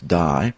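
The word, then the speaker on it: die (δεῖ): When we talk about this, we're talking about the Greek word, number 1163 in the Strong's Concordance, which is DEI, which we would pronounce die.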